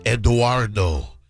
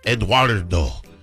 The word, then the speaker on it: Edoardo: It's Eduardo, spelled E-D-O-A-R-D-O. So like the Latin pronunciation of my name. Eduardo.